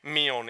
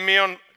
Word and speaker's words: Milne: It's a man named A.W., I believe he pronounced his name Milne, M-I-N-M-I-L-N-E, Milne, Milne, whatever.